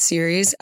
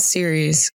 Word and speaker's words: Ceres: So here I am stating before the show starts that I vow to pronounce Ceres as Ceres and not Ceres moving forward.